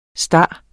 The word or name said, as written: star